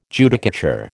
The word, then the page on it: Judicature: American English